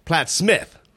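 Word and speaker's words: Plattsmouth: Platt's mouth is pronounced Platt Smith.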